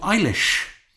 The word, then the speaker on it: Eilis: It is the story of Eilish. – Say my name again? – Eilish. – Eilish. – E-I-L-I-S.